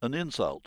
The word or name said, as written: insult